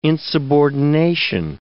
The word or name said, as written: insubordination